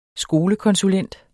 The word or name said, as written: skolekonsulent